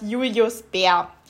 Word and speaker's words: Julius Bär: Und jetzt beim Beach Polo war es Julius Bär das ist ein Schweizer Unternehmen. Ich kann jetzt nicht so super Schweizerdeutsch. Ich würde es aber immer eher Deutsch als Englisch an, aussprechen.